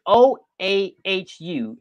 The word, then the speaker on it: Oahu: Honolulu, but it's it's O A H U.